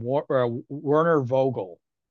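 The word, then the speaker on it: Werner Vogels: I mean, well, I'm going to butcher his name, Werner Vogel.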